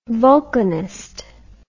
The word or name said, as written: vulcanist